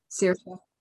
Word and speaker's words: Saorsa: I don't know if I'm pronouncing it correctly, but that's the Gaelic word, S-A-O-R-S-A. – Oh, yeah, Sirsa, I think. Is it Sirsa? – Sirsa.